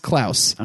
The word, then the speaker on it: Klaus: It's spelled Claus, but it's pronounced Klaus.